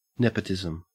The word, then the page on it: nepotism: Australian English